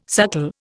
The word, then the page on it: suckle: Indian